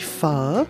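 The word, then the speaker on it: phở: Comment dit-on ? – On dit pho. – Pho. Ah. Donc c'est comme s'il y avait un point d'interrogation à la fin, en fait, du mot.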